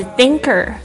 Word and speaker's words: thinker: 接下来是 thinker，思想家，思想的人。好，这几个字老师再拼一下。 doer 就是 D-O-E-R， thinker T-H I-N-K-E-R，特别注意一下 TH 的发音是这个这个轻轻的咬住舌头，上下排牙齿轻轻的咬住舌头，然后发出这个气音 Thinker Possibility 或者 Possibility OK P-O-S-S-I-B-I-L-I-T-Y，它的复数呢是 P-O-S-S-I-B-ILITY L-I-T-I-E-S，特别注意一下变成复数，说把 Y 去掉加上 I-E-S，就是可能性，可能发生的这些事物叫 possibilities。